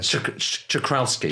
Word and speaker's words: Czochralski: Czochralski. – Czochralski.